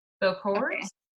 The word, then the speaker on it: Bokors: So, in voodooism, there are these people called, I don't think I'm saying this right, but Bokors. It's Bokors. Bokors.